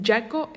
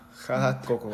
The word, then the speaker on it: Jaakko: Eh, Jaco Eino Kalevi, pero Jaco se escribe con J-A-A-K-K-O. – Ah, Jaco.